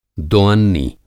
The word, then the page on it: दोअन्नी: Hindi